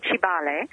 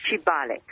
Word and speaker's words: Kibaale: It's in Kibale. – Okay. – It's spelled K-I-B-A-A-L-E. – Okay. – There is another city that is called Kabale that I've been involved in, but this one is Kibale.